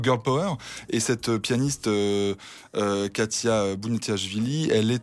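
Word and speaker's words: Khatia Buniatishvili: Alors, ils ont trouvé une artiste incroyable qui s'appelle Katia Bouniatichvili. Qui est-elle ? – Très bien prononcé.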